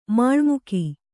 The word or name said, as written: ಮಾಳ್ಮುಕಿ